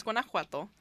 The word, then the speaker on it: Guanajuato: So it's pronounced Guanajuato. It's G-U-A-N-A. Right? Did I say that right? – Yes. Yes. – N A. – J. – J U A T O.